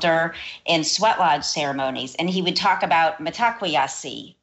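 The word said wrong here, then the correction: Mitákuye Oyás'iŋ: all our relations. I hope I'm pronouncing that properly. – You did fine. Metakwayasin, yeah. – Thank you. I knew it was slightly different.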